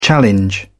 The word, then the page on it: challenge: British English